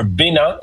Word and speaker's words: Bénin: But first, today we're headed to Benin, or Bénin, as it's pronounced in French, a country in West Africa with deep history and powerful connections to the Black diaspora.